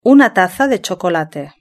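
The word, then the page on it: una taza de chocolate: Spain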